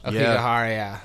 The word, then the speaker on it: Aokigahara: I've heard Aokigahara, which I imagine is maybe a little. – That's more. – Probably Aokigahara. – Aokigahara, yeah. – Aokigahara, yeah. That's it.